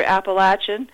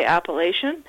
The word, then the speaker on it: Appalachian: The further south you go, you'll hear Appalachian, and they'll say that that's the right way to pronounce it. And up here, we often say Appalachian.